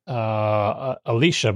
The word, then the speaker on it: Elisha: Alicia, but it's spelled E-L-I-S-H-A.